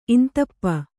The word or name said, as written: ಇಂದುಪ್ಪು